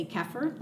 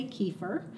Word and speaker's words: kefir: Well, there's actually three ways to pronounce kefir. It's a cultured dairy food, and you could say kefir like I am. Other people say kefir. That's very popular in the United States. You can also say kefir, which is another popular but maybe overseas way that kefir is pronounced.